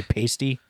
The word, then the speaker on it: Paiste: And then there's Meinl, and then I think it's pronounced Paiste. Or I might say Pasty.